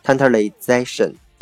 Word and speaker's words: tantalization: OK，最后一个词呢，它是这个 tantalize，它的这个名词形式啊，叫做 tantalization。 tantalization，OK，注意这个词的读音啊，还有它的元音发生了变化。